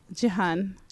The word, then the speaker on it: Jihan: Jihan. – Is it like a G or a... – It's like a eh.